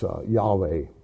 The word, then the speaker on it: Yahweh: They don't have J. It's Y sound there. So it's Yahweh.